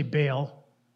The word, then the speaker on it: Baal: Now, Baal, some say Baal, but Baal is how uh, it should be pronounced, was a god worshipped in many ancient Middle Eastern communities, especially among Canaanites.